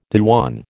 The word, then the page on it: dewan: American English